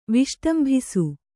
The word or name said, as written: ವಿಷ್ಟಂಭಿಸು